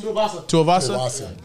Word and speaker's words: Tuivasa: How you say his name? – Tuivasa.